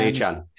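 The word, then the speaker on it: Achane: Is it Achan? Is that how we pronounce it? – Yeah, Davon Achan.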